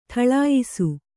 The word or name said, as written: ಢಾಳಯಿಸು